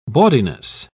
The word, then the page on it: bawdiness: British English